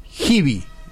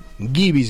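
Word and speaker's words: Giby: Se escribe G de Guillermo, I de Indio, B corta de Víctor, Y de Yankee. Gibi, Gibi se pronuncia, pero se escribe así, Gibi.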